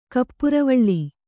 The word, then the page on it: ಕಪ್ಪುರವಳ್ಳಿ: kappuravaḷḷi